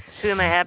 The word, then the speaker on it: Huma: Huma Abedin.